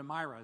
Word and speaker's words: Myra: And then they go from Sidon to Myra, or to Myra is how it's pronounced.